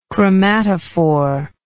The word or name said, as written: chromatophorous